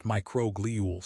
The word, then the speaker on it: microglial: Research shows that microglial, pronounced microglial cells, which are the immune sentinels of the brain, shift into a destructive inflammatory state when the body is under metabolic stress.